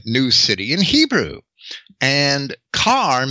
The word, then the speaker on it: Caer: Now, the word care, C A E R, spelled in C A E R, in Britain was a town, right? – It yeah, I was just going to say, town. it still is. It's, yeah, and it's pronounced car as well.